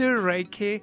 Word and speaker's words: Reiki: Reiki? Am I saying that correctly? – I do Reiki. – Reiki? – And also all my ability.